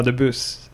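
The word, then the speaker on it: du Bus: Jag tror att S-et ska uttalas. – Gervais debut. – Ja, debuss. – Ja, Gervais debuss.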